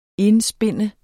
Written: indspinde

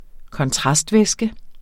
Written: kontrastvæske

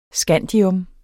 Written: scandium

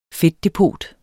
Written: fedtdepot

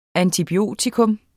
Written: antibiotikum

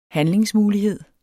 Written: handlingsmulighed

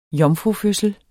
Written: jomfrufødsel

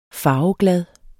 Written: farveglad

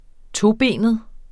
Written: tobenet

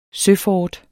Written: søfort

